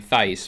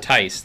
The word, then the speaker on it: Tice: I've been saying Thice, but Tice. – The H is silent. – Yes. T-I-C-E is what we put down at restaurants.